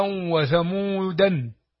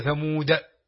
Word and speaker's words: ثمود: قوارير وراها ألف، ثمود وراها ألف، قالوا وراها ألف الواو. فالذي يقرأ المصحف من غير شيخ يقول ماء، يعني يفسد الآية، يقول قالوا و، يقول وعادا وثمودا، هي ثمود على القراءة اللي فيها الألف زائدة.